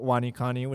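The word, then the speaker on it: WaniKani: We just want to let you know about Wani Kani, which is our Japanese kanji and vocabulary learning application. It's spelled Wani, W-A-N-I. – Kani. K-A-N-I.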